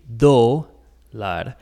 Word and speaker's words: dólar: So keep in mind that with the word dólar, there is an accent mark on the O, meaning that we put emphasis on that O, the dólar.